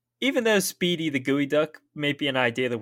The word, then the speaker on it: geoduck: So, geoducks are a species of large saltwater clam found in the Pacific Ocean, as we already mentioned. I'll say again, it is spelled geoduck, but is pronounced as geoduck.